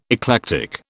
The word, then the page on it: eclectic: British English